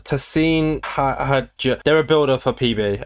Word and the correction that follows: Tussin: Is it Tussin? Yeah, Tussin.